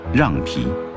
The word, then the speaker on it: 酿皮: 虽然全国各地都叫酿皮，但敦煌人还是固执地坚持古代读音，瓤皮。